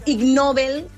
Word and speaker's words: Ig Nobel: Ve de ser Ig Nobel, que se pronunciaria igual com innoble, no?